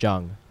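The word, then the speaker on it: Zhang: C-I-S-S-Y. – That's a sick name. – Sissy Z-H-A-N-G. I think it's pronounced Jung. That's Chinese.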